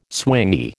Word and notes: swingy: American English

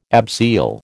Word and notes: abseil: American English

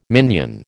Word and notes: minion: American English

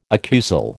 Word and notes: accusal: American English

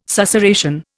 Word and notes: susurration: Indian

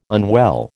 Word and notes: unwell: American English

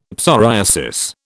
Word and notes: psoriasis: British English

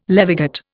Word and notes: levigate: Indian

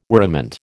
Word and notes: worriment: American English

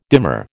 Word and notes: demur: American English

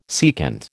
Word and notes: secant: American English

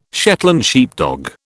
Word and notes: Shetland sheepdog: British English

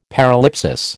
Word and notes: paralipsis: American English

